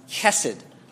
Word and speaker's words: chesed: It's a Hebrew word which is pronounced chesed.